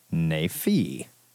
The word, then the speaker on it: Naifeh: This week, I picked Princess Ugg number one from Oni, written and drawn by Ted Naifeh. That's how you pronounce that.